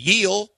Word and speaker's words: Gil: The pitcher for the Yankees the other night was spelled GIL, and they were saying it was Yeel.